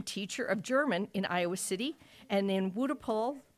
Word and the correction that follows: Wuppertal: Close enough? If I'm saying it right, Wutapol, Germany? – Wuppertal.